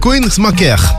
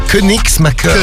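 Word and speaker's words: Koenigsmacker: Alors, Koenigsmacher. – Koenigsmacher. – Voilà, en Moselle, bref. – C'est Koenigsmacher.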